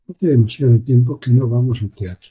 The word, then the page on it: teatro: ispanyolca